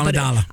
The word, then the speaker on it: Amidala: Amidala.